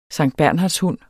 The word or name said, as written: SANKTBERNHARDSHUNDEN